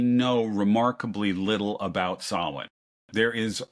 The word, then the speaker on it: Samhain: It's pronounced slightly differently depending on where you are, but it's never spelled like it sounds. It's Samhain. It looks like Samhain, but it's pronounced Samhain.